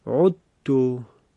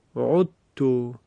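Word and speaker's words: عُذْتُ: وقتی ادغام میکنیم به این شکل تلفظ میشه این فعل، عذتو، عذتو، یعنی کاملا حرف زال از بین می رود.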